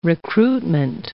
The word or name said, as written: recruitment